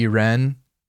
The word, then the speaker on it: Irene: And then she's saying that girl's name in the climax, and it keeps saying Iran. – Yeah, her name is Irene, but I think they're doing, like, a French pronunciation, so it would be Irene.